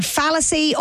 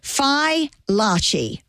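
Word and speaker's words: Fai Laci: So it's spelled F-A-I and then next word, L-A-C-I. So it's Phi La Chi. They're from Boston. Phi, F-Y-E, L-A-H-C-H-E-E. Because I've been saying fallacy or phylacy, but no. So Larchi, Phi Larchi, rhymes with botchy, Larchi. So Phi Larchi.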